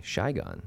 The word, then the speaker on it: Saigon: Then we went to Vietnam, and we went to Nha Trang and Ho Chi Minh City, also known as Saigon, or as the locals call it, Saigon. – Oh, really? Saigon. – That's how they pronounce it.